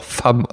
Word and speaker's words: Femme: Aber genauso wie man äh, Möd schändend einfach Moet et Chandon ausspricht, ja, spricht man La Femme auch Femme aus.